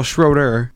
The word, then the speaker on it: Schroeder: I've been saying his name wrong my entire life. Why have I been thinking Schrodinger? That's Schrodinger's cat. Yeah. Well, Schroeder. So, Schroeder.